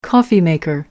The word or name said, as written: coffee maker